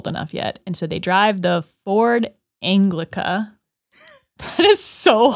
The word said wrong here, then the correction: Anglia: No, it's Anglia. There's no C in it. Ford Anglia.